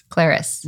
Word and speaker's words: Claris: So let's jump right into, now are we calling it Claris Health or Clarice? – Claris.